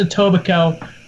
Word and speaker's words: Etobicoke: Etobicoke. I mean, it is dumb that it's pronounced Etobicoke when you've got Coke at the end of it.